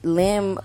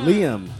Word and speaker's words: Liam: Yeah, she's been seen with Kaitlynn Carter on boats and having lunch with her mom, and li- supposedly Lim wanted kids. Mm. – Liam.